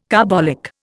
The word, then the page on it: carbolic: Indian